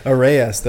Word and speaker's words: arayes: It's spelled A-R-A-Y-E-S.